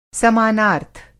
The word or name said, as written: समानार्थ